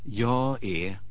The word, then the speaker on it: jag är: Добавим, что шведы часто не произносят, проглатывают последнюю согласную, и вместо «jag är» слышно «я е».